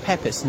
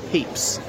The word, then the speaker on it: Pepys: I know it's spelled Pepys. One of his descendants told me it is pronounced Pepys, not Pepys.